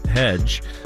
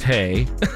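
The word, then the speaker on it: hej: I was going to say hedge, but it's pronounced hay. – Okay. – Whenever I go to IKEA, you see on the door, it says hedge. H-A-H-E-J, but it's not pronounced, it's hay, I believe.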